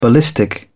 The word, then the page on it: ballistic: British English